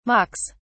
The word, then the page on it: mocks: British English